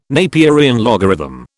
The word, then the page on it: Napierian logarithm: British English